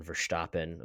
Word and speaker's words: Verstappen: I think I want to start kind of at the beginning of kind of the whole, I put a hard emphasis on Verstappen. I'm trying to be as F1 friendly as possible by using all of the correct pronunciations. – Dutch accent.